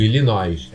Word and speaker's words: Illinois: Isso, Illinois, é isso.